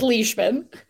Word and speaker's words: Leishman: It's Leishman.